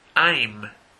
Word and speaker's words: I'm: I'm. I'm. I'm. Muy común ver alumnos no pronunciar esto correctamente. Dicen I, I o ein. Tienen que cerrar sus bocas. I'm, I'm.